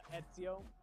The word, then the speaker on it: Ezio: I know now that it's that it's uh, Ezio because of the way it's pronounced in Italy.